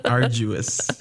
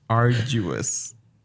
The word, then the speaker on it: arduous: Arguous. – Arguous. Arduous. – We've been arguing a lot. Yeah. Just kidding. – It's been arduous. Right. – Arduous. Yes.